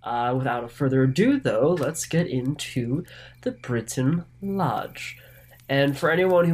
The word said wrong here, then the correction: Brinton: might be curious, it's not actually spelled Britain, it's B R I N T O N so Brinton is probably the proper pronunciation, but I'm probably not going to be able to stick to that.